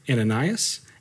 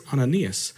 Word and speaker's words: Ananias: If you've heard the story before, you might have heard the man's name as Ananias, and I might accidentally say that, but I learned this week that that is not the correct way to say it. It is Ananias.